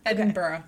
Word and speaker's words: Edinburgh: So you say it like borough? – Yeah, it's more like how you say it. Edinburgh.